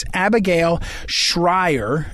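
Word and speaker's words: Abigail Shrier: Again, when we come back, and I'll put up on social media Abigail Shires. Excuse me. I always mispronounce her name. It's Abigail Schreier. S-H-R-I-E-R.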